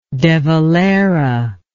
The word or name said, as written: de Valera